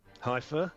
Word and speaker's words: Haifa: is it, forgive my pronunciation on this one, Haifa in Israel? – Haifa, yeah.